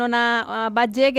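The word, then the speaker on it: Ona Batlle: Pues ya teníamos muchas ganas de hablar aquí en Ellas Juegan, este podcast de Onda Cero con Ona Badge. ¿Qué tal, Ona? ¿Cómo estás? – Hola, muy bien. – ¿Lo he pronunciado bien antes de nada? – Sí, sí, muy bien.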